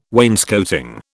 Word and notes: wainscoting: British English

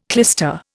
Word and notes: clyster: Indian

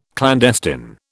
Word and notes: clandestine: British English

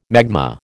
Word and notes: magma: American English

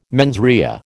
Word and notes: mens rea: American English

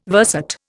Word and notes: verset: Indian

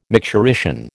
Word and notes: micturition: American English

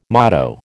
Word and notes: motto: American English

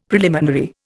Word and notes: preliminary: Indian

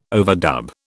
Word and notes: overdub: British English

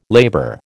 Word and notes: labour: American English

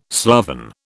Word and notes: sloven: British English

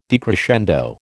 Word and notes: decrescendo: American English

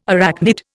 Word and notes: arachnid: Indian

